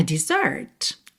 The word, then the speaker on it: dessert: Agora, se você quisesse falar sobre uma sobremesa em inglês, chocolate mousse, a lemon pie, you would say a dessert. Exatamente a mesma pronúncia,